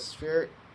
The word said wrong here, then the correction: Xperia: Xperia. It's the Xperia.